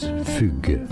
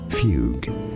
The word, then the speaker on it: fugue: In French, it's said as fugue, fugue. But because this U sound from French doesn't exist in English, fugue, fugue is how it's said in English.